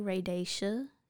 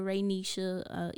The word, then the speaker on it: Raydesha: I know it looks like Raydisha. Some of y'all what? Say, yeah, oh, I've heard Raydisha, Raynisha, even though there's no N in my name. Raydija, like Khadija, I don't, Raydisha, R-A-Y-D-E-S-H-A, and Denise is spelled with an S, not a C.